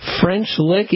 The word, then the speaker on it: French Lick: Let's go to French Lick, Indiana.